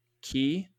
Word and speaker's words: quay: But the song goes on to say, and the band played Waltzing Matilda as the ship pulled away from the key. It's spelled quay, but pronounced key.